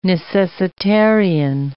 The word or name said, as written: necessitarian